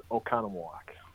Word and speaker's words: Oconomowoc: The, the town I'm in is Oconomowoc, Wisconsin.